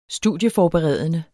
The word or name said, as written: studieforberedende